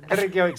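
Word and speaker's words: Reykjavík: Reykjavik, sí, Reykjavik.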